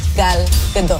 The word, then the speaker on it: Gal Gadot: Today, I'm going to teach you how to pronounce the name Gal Gadot. – Gadot. – Gadot. Gal Gadot.